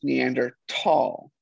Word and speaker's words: Neanderthal: But it's technically, to my knowledge, correctly pronounced Neanderthal, because it is Neander, which is the name of the valley that the first skull was found in, and Tal is valley in German. So Neander Valley, Neanderthal.